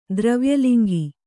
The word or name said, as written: ದ್ರವ್ಯಲಿಂಗಿ